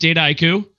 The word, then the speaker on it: Dataiku: It's so I pronounce it Dataiku. The way that it's described is it's a combination, it's a portmanteau of data and haiku, the Japanese poems.